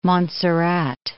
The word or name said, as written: Montserrat